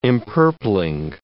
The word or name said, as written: empurpling